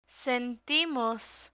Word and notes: sentimos: spanish

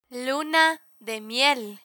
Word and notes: luna de miel: Spanish

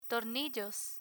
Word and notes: tornillos: spanish

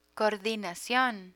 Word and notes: coordinacion: Spanish

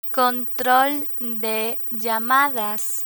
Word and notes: control de llamadas: Spanish

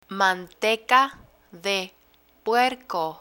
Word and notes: manteca de puerco: spanish

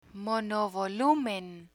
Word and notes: monovolumen: Spanish

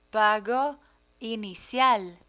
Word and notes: pago inicial: Spanish

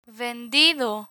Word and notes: vendido: Spanish

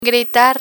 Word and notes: gritar: Spanish

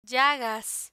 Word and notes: llagas: Spanish

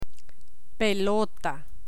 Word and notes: pelota: spanish

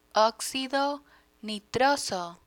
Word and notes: oxido nitroso: Spanish

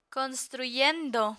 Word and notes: construyendo: Spanish

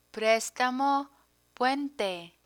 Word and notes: prestamo puente: Spanish

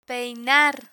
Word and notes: peinar: Spanish

